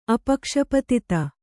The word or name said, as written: ಅಪಕ್ಷಪತಿತ